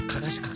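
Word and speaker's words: 案山子: あ、かかし、かかし、かかしって読むんだこれ、うん、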